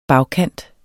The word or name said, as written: bagkant